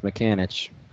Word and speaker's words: Mikanich: I think it's Mikanich. Mikanich. I know this. I had to deal with this last year. It's spelled funny.